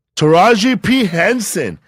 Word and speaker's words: Taraji P. Henson: How do you say her name? Taraji P. Henson.